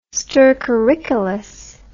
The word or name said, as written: stercoricolous